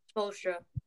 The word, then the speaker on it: Spoelstra: I think that, you know, if you watch some of the Heat games, his bonding with the coach, how do you say his last name? Spoelstra? – Spoelstra.